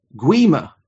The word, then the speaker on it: Guima: Guima. Is how it's saying. The gui sounds like gui, similar to the word we with a hard G. Guima.